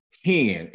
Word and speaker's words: hen: So the Hebrew word that you would find that that's equivalent with the English word grace, the better word is favor, is the Hebrew word hen. It's spelled het nun. Het nun.